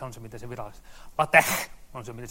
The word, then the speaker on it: LaTeX: Se kuulemma pitäisi olla sillä tavalla, että se LaTeX, joka on tämä XI tuolla, niin sen pitäisi olla niin voimallinen, että edessä oleva monitori kostuu. Virallinen määritelmä sille, mitä tuo lausutaan.